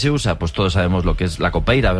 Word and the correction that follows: capoeira: ¿verdad? Capoeira. Qué mal pronuncio últimamente.